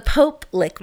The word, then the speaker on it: Pope Lick: Now, if you're interested in more information about the Poke Lick Monster. – Pope. – Right. Oh, yeah, I said it wrong this time. Jeez. The Pope Lick Monster,